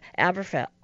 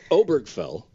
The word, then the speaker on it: Obergefell: Aberfeld. How do you say that word? – Oh, it's Obergefell? – Obergefell.